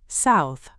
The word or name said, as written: South